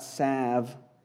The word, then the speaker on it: salve: The last part here is this, is it talks about salve. Is it salve or salve? Help me out here. Salve, no L, silent L.